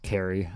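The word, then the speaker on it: Cary: And I kept saying the city in North Carolina was Curry. And it's totally pronounced Carry.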